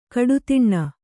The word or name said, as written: ಘಾಡತನ